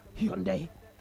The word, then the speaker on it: Hyundai: They know they talk word like I heard the word H-Y-U-N-D-I-A. What do you pronounce it as? – H-Y-U-N-D-I-A. – That is a brand now. Uh-huh. They say that Hyundai.